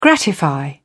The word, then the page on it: gratify: British English